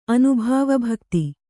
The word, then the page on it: ಅನುಭವಭಕ್ತಿ: anubhavabhakti